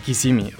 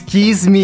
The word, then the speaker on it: Kissimmee: Se pronuncia Kisimi. O sea, estamos, lo estamos mencionando bien. Te lo digo porque varios colegas de repente dicen Kisimi. – No es Kismi. Kismi como la canción.